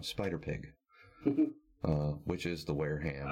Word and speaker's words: Wareham: But see, it's W-A-R-E. It's a town in Massachusetts. – W-A-R-E? – W-A-R-E-H-A-M. Wareham. – Wareham? – Would it be?